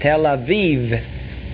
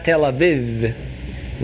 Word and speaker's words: Tel Aviv: There's only E. It's Tel Aviv. Tel Aviv. And not Tel Aviv. With a good American accent.